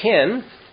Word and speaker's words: chen: And it translates the Hebrew word ken, C-H-E-N. Some might say chen. A little more guttural pronunciation there.